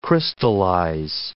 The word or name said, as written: crystallize